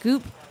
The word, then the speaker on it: Goop: I would just like it to be noted that Gwyneth Paltrow's website, Goop, rhymes with poop.